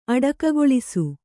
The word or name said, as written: ಅಡಕಗೊಳಿಸು